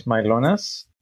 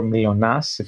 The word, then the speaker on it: Mylonas: I'm Harris Mylonas, or Mylonas, if you want to go with my actual pronunciation of my name.